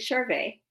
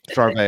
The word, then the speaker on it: Charvet: First of all, it's David Charvet. – Charvet. Oh, Jesus. I'm sorry. I should know. My last name has a T in it, too, and everyone pronounces the T. The T doesn't count.